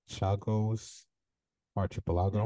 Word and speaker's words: Chagos Archipelago: 1966, the Britain expels some 2,000 residents of the Chagos Archipelago.